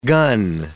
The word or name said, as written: gun